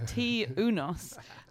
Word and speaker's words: Tŷ Unos: And I went to the trouble, because I don't want to offend anyone, of looking up how you pronounce it. And it's called Tŷ Unos, which means one night house.